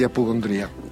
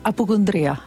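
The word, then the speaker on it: Appocundria: Scusami, no, fammi sentire la pronuncia esatta di apocondria. – Apocondria. – Sì, poi dipende quanto sei arrabbiato, quanto sei, però è apocondria.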